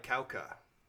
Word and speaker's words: Jauja: And then I also got certain scenes, some of the more quieter moments were kind of like Kauka, which is a movie I love, and it's spelled J-A-U-J-A if you want to look it up.